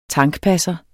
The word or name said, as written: tankpasser